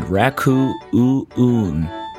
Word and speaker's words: Raccoo-oo-oon: You just heard Ryan Garbes and Shawn Reed, two old friends who shared many projects together, including Wet Hair and Raku-oon. Believe it or not, that's actually how it's pronounced.